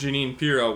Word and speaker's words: Jeanine Pirro: And Judge Jean Pirro, who I think, am I saying that right? Is it Jeanine Pirro? I'm not exactly sure. – Oh, it's Judge Jeanine. – Yeah, Judge Jeanine Pirro was sort of defending the, you know, justice system, saying that we have to accept this verdict.